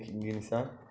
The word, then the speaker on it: Gnizá: Guinizá cuando termina Tisha B'Av. – ¿Cuál era? La Gnizá. ¿Cómo se dice Gnizá? Entrada. – Gnizá. Gnizá, Gnizá.